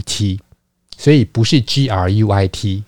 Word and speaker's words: Gruit: 那这个 Hout，它其实用英文的来发音的话，会念成 Groot。所以很多人都念成 Groot，其实这个字它不是英文，它是 Gruit，它的拼法是 G R U I T。